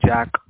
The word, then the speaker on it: Jack: Yes, it's Jack, J-A-C-K.